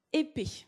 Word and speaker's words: Lépée: On prononce épée. C'est le duc de Brissac lui-même qui l'indique dans ses souvenirs d'avant-guerre. Donc, Jeanne de Lépée a été directrice des salons chez Jean Patou entre 1933 et 1939.